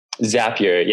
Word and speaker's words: Zapier: but is it Zapier? – Zapier, yeah. Their slogan is happier with Zapier, so that's how I always remember it. – How exactly does that work? – So Zapier has is built around workflows.